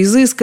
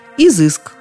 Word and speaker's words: изыск: Нас интересовало ударение. И его мы благополучно выяснили. Изыск. И только изыск.